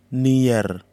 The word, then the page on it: niar: Kwamera